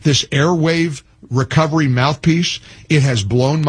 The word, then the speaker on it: Airwaav: Airwave is it's Airwave, but it's spelled A-I-R-W-A-A-V. So remember that W-A-A-V spelling.